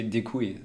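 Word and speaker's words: děkuji: Äh, meine Aussprache ist sicherlich nicht perfekt, aber es müsste sowas wie Dekui heißen.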